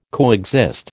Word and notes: coexist: American English

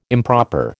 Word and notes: improper: American English

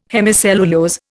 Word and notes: hemicellulose: Indian

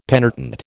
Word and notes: penitent: American English

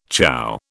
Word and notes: chough: British English

